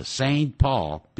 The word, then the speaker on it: Saint Paul: 19 题答案是 C Minneapolis 当然跟那个 Saint Paul 不要念成圣保，圣保罗叫 Saint Paul 是双子城，